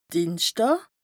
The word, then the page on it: Dìenschdàà: Bas Rhin; Strasbourg